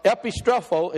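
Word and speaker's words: epistrepho (ἐπιστρέφω): But epistrepho is how you say it, down at the phonetic spelling, epistrepho.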